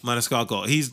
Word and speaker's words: Maniscalco: Again, dude, I Sebastian Maniscalco, or how do you say his last name again? – Maniscalco.